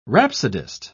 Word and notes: rhapsodist: British English

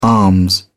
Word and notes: arms: British English

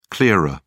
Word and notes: clearer: British English

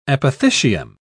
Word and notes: apothecium: British English

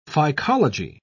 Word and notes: phycology: British English

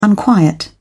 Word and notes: unquiet: British English

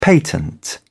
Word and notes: patent: British English

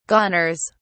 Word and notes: goners: British English

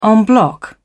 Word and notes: en bloc: British English

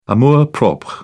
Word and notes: amour propre: British English

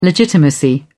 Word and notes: legitimacy: British English

